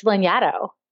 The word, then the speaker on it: Lanyadoo: It's Lanyadoo. It's not English. It's not phonetic.